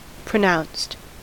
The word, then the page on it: pronounced: American English